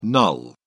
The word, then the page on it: null: British English